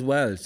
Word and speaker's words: Walsh: It's spelt Walsh, but everybody says Welsh. Does anybody say Walsh, really? No? Mostly in Munster, they say Welsh.